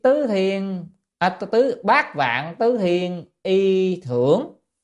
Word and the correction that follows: y thường: y thường nhé, chữ này đọc là thường, tức là lại dùng mà 84.000 cái gì y phục, cái chữ thường này đó, chữ thường này chính là cái quần.